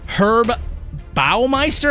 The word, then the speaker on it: Herb Baumeister: And it is the Indianapolis serial killer who goes by the name Herb Baumeister.